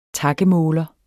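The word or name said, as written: TAKMÅLER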